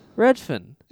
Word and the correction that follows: Refn: What's his actual name? – Nicholas Winding Refn. – Refn, right. Nicholas Winding Refn.